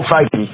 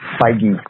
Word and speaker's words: Feige: Feige? Feige? You know what I'm talking about? – No. – The head Marvel guy. – Oh, Feige. – Feige, yeah.